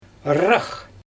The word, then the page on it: rraax: Xyzyl